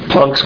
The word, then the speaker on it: Planck: Planck's constant, like for Max Planck. The Germans pronounce it Planck, not Planck. P-L-A-N-C-K.